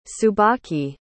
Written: tsubaki

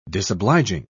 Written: disobliging

